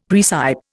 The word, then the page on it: praecipe: Indian